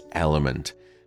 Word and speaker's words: LMNT: A sponsor of today's podcast is Element, and that's spelled L-M-N-T.